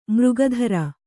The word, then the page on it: ಮೃಗಧರ: mřga dhara